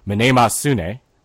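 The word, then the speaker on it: Mnemosyne: called Minemasune. That is, again, the proper pronunciation in Greek.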